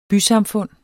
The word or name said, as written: bysamfund